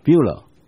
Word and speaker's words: Beulah: 所以神的百姓，神希望我们常常记得，我们正在 heading for Beulah，就是我们正在前往一个地方，在圣经里面称它叫 Beulah，这是希伯来文直接音译过来的英文叫 Beulah。我有 PPT，对，这个 Beulah 英文读作 Beulah，这个字是它原来的意思有婚姻关系的意思，联合的意思。